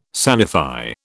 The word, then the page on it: sanify: British English